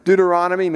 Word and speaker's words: Deuteronomy: Deuteronomy, maybe not so easy to remember, rhymes with hominy if you've ever eaten hominy.